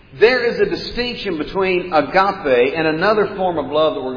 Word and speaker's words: agape: Now, if we were to spell it in English, it would look like agape, A-G-A-P-E. But it's pronounced agape. Here's a good way to remember it, though, because when you see it, you'll do this. And your mouth will be agape, because that's what agape looks like.